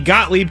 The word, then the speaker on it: Gottlieb: The Cavs are hiring University of California Berkeley women's coach Lindsay Gottlieb to be an assistant coach on John Beilein's staff. Did I say that right? – Yep.